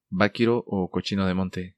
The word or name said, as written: Baquiro o cochino de monte